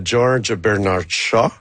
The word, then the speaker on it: George Bernard Shaw: Ben, l'inspiration vient de la pièce de George Bernard Shaw, que j'ai prononcé comme à merveille.